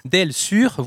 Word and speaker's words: Del Sur: Je ne vais pas le prononcer à l'argentine parce que les gens vont penser que c'est un spectacle pour les malentendants. Del Sur, voilà, pour prononcer à la française, hommage à Astor Piazzolla, bien entendu.